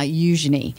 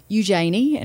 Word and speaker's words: Eugenie: I'm kind of interested in Beatrice and Eugenie. Hang on, let's get this right. Eugenie. I practiced a lot of times before her wedding and I've got it down pat now. Eugenie. So not Eugenie.